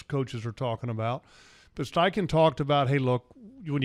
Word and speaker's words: Steichen: Well, I mean, first of all, and if you had a chance to watch Steichen, did I say that right? – You did. – Okay, Steichen,